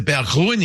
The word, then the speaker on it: Berggruen: It's part of the Berggruen. – Bergwin. The Berggruen Institute.